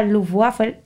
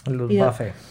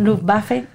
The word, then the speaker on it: Luftwaffe: Incluso la Fuerza Aérea, la famosa Luftwaffe, no sé pronunciarlo. – Luftwaffe. – Perdón, Luftwaffe, disculpadme. la utilizaba también en una versión, pero con un modelo modificado.